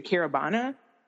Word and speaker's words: Caribana: And also, I remember reading that you went to Caribana.